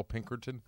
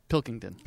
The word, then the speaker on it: Pilkington: Pilkington.